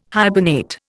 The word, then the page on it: hibernate: Indian